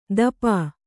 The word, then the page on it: ದಪಾ: dapā; kannada